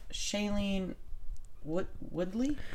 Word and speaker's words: Shailene Woodley: Okay, what I want to talk about is Shailene Woodley.